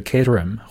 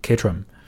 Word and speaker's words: Caterham: 但是呢，很多时候这个音 h 都是不发出来的，所以想提醒大家，见到这种词的时候，不要把它读成火腿啊，不要把它读成 ham，它这个 h 是不发音的，所以是 caterham 或者是 caterham，嗯。